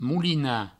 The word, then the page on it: molinar: moulina